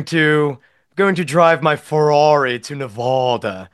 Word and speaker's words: Nevada: So the proper way to say it is Nevada, not Nevada.